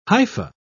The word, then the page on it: hypha: British English